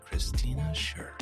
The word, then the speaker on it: Kristina Sherk: In this episode, we're joined by Kristina Sherk.